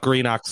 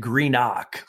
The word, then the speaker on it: Greenock: I'd love to know a little bit more about Greenock, Scotland. – Don't ever pronounce it Greenock like that. It's Greenock.